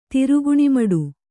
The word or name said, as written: ತಿರುಗಣಿಮಡು